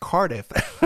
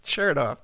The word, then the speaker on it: Chertoff: I used to pronounce, I thought his name was pronounced Cardiff. His name was pronounced Chertoff.